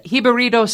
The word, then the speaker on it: Jibarito: This is Celie Rodriguez, and she is the owner of the Jibarito Stop.